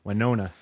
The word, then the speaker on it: Wynonna: Wynonna. Yes, sir. I never know how to pronounce that because I never hear people say it out loud. Wynonna.